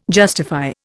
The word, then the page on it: justify: Indian